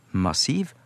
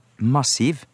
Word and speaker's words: massiv: Men i moderne, normert bokmålsuttale, og nynorsk også, så er de fleste nå samde om at både massiv og massiv, til dømmes i NRK-en, må være heilt korrekt, altså valfritt. På samme måte som maritim nå også er fullt gangbart som korrekt uttale ved sida av maritim.